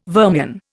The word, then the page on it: vermian: Indian